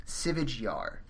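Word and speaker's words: Sivyj Yar: So the way it's spelled anglicized is S-I-V-Y-J space Y-A-R. Sivij Yar.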